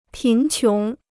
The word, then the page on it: 贫穷: pín qióng